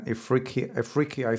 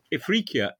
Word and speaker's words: Ifriqiya: Let's talk about chapter 3 of the book, where you talk about Sicily and Ifriqiya, if I'm pronouncing it correctly. – You are. Yeah. – What were the economic activities like? – Well, Ifriqiya